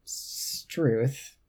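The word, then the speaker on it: strewth: Because I looked it up, and it is like an abbreviation for God's truth, which should mean that it's pronounced struth, because that's struth? – Right. God's truth. Struth.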